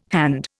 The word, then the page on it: hand: Indian